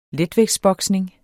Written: letvægtsboksning

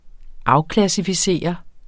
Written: afklassificere

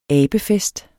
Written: abefest

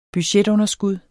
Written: budgetunderskud

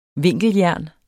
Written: vinkeljern

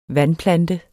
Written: vandplante

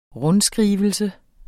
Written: rundskrivelse

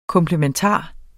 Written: komplementar

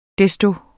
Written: desto

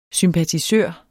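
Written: SYMPATISØRER

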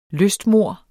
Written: lystmord

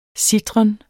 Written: sitren

